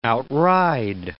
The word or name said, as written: outride